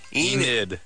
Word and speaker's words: Enid: Is it E-A-T-O-N? – Is she saying Eden or Eden? Or how do you spell it? – E-N-I-D. – Enid. – Like an old lady's name.